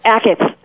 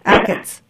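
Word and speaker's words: Achatz: I know, but is Alinea's chef, how do you say his name? A Shots? – Oh, a Shots? – Ackett. – Ackett. Thank you.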